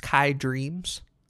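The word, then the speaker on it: Khai Dreams: All right, going back into indie territory again, we got another artist, Kai Dreams, I think is how you pronounce it. K-H-A-I Dreams.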